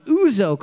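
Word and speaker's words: ouzo: But I know for sure that I am pronouncing Uzo correctly.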